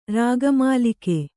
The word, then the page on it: ರಾಗಮಾಲಿಕೆ: rāga mālike